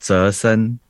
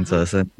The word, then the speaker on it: Zayson: It's like Zayson, Huang Zayson, but they would say, oh, it's like Jason, Zayson, you know.